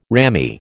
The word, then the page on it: ramie: American English